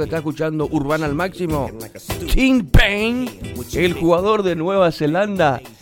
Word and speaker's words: Tim Payne: El jugador de la selección, escuchen, de Nueva Zelanda, Tim Payne. El nombre Tim, apellido Payne. ¿Está bien pronunciado, Luis Fer? – Tim Payne.